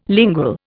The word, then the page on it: lingual: Indian